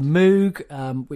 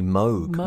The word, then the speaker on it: Moog: They had one for Moog, which I'm terrible pronouncing wrong, actually. But anyway. – It's supposed to be Moog. Robert Moog.